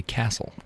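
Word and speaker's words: Kasl: It's a fabulous book by Charlotte Kasel, K-A-S-L. I'm not sure if that's the correct pronunciation. And it's a handbook for finding love on a spiritual path. – So I think it's actually Castle.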